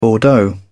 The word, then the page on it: bordeaux: British English